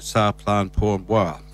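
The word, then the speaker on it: Ça plane pour moi: Before that, the Presidents of the United States of America doing their version from Plastic Bertrand's, I got to say it right, Sa Plan Pour Moi.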